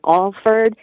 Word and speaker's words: Alford: I just wanted to confirm, my last name is Alford, and it's spelled A-L-F-O-R-D. We pronounce it Alford, and I don't think he was saying Al Ford.